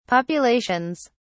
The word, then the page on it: populations: British English